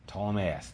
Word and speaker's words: Ptolemais: When we had finished the voyage from Tyre, we arrived at, come on, how do you say a word that starts with P-T-O-L? Ptolemais. And what is it? – Ptolemais. – There you go.